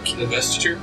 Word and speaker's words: investiture: Investiture? – Investiture of flame, where I draw that 15-foot line.